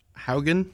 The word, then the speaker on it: Haugen: How do you pronounce her name? – Francis Hagen. – Yeah. Okay, we're gonna go with Hagen.